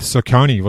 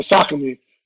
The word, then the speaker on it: Saucony: I call it Saucony, but some people call it Saucony. – Oh, Saucony. Okay. In Australia, we say Saucony, so I don't know how you pronounce it over there, because Adidas is called Adidas over here in Australia, so it's a bit strange, isn't it? – Yeah.